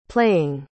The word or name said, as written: playing